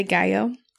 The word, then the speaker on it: Gallo: It's spelled G-A-L-L-O, but she's from Ecuador, so we're going to say Gallo.